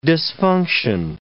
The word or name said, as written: disfunction